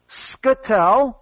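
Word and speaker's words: Scytl: The votes cast by Americans were counted by a bankrupted Spanish company, Scytel, and I'm not sure I'm saying that right, it's S-C-Y-T-L, S-C-Y-T-L, Scytel, Scytel, in Spain.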